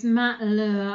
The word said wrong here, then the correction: Lauer: I don't really recognise. – Lauer? – Lauer, is that how you say it? – I think it was Matt Lauer.